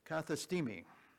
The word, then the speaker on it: kathistemi: And that Greek word, appoint, is kathestemi, kathestemi. I'll spell that. K-A-T-H-I-S-T-E-M-I, kathestemi, or kathestemi, I believe.